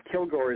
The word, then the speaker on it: Kilgour: Kilgore is actually spelled K-I-L-G-O-U-R, not the way they spell it in the States usually, O-U-R.